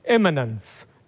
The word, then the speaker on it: immanence: Immanence, immanence, immanence, immanence, not imminent, that'd be immediate, immanence, which means God within.